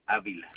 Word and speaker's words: Avila: Alberto Avila.